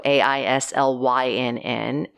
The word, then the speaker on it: Aislynn: I believe the pronunciation, the original pronunciation was either Ashlyn or Island. Both of those would have been like a Gaelic pronunciation. Sure. But my mom changed it to L Y N N, so A I S L Y N N, and pronounced it Aislynn.